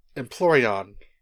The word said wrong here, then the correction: Empoleon: Wow, I've been saying it wrong this whole time. – Fire Eevee is Flareon, and don't worry, we'll talk about Eevees later. Hold that thought. – Empoleon. – Empoleon. – Think of it like Napoleon, except a penguin.